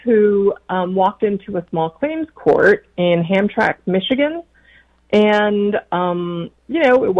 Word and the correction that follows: Hamtramck: Oh, please let me interrupt for just a moment and correct your pronunciation. It's Hamtramck. – I know. – And I'm only doing that in the spirit of friendship. You've been so gracious to teach me how to pronounce some things. And I am familiar with that town, and it's pronounced locally Hamtramck.